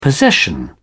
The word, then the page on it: possession: British English